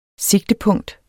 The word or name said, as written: sigtepunkt